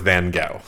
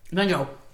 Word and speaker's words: Van Gogh: We are aware that there are different pronunciations for Van Gogh, or whatever. We're going to go with Van Gogh. – Yeah. Van Gogh. – Because it's the right one.